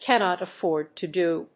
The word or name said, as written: cannot afford to do